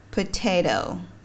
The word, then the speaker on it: potato: ví dụ khác như là chữ potato potato potato thì thay vì đọc là potato thì mình đọc đó là potato chữ t nằm giữa nguyên âm a và o và không nhận dấu nhấn nên mình đọc nó là potato